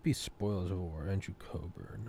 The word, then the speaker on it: Cockburn: Pronounces it Coburn, it's Cockburn.